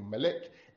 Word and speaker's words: Melek: I'll spell that for you. Melek, M-E-L-E-K, Melek, right?